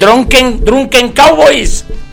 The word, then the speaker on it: Drunken Cowboys: Drunken Cowboys.